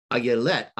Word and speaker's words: ayelet: So the Hebrew pronunciation of deer there is ayelet, ay. So it's got the yod in it, yelet.